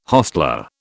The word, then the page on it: hostler: British English